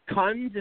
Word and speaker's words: Kuhns: Kunz. Is that how I pronounce it? – Kunz, like raccoon.